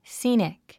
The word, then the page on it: scenic: síːnik; スィニック